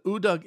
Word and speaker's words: Udug: And by the way, this is spelled U-D-U-G. Kind of like Dig Dug, but Udug.